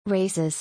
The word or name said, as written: Races